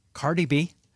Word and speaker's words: Cardi B: A year ago, everyone from the strategists and Wall Street banks to rap artist Cardi B, I don't know.